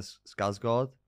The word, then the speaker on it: Skarsgård: It's Alexander Skarsgård. Is the correct pronunciation? That's a good pronunciation.